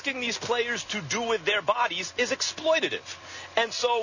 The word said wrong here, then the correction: exploitative: okay, first of all, the word, Mr. Kellerman, is exploitative. Learn the emphasis and the accent on the right syllable.